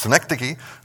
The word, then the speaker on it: synecdoche: It's synecdoche. And so I've got the pronunciation guide up there, synecdoche.